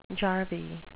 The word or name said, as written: jarvey